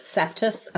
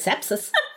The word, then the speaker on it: sepsis: Pronounces sepsis septus and it drives me insane. – Septus. – I had septus. No, you didn't. You had sepsis. Nearly killed you and you can't even pronounce it.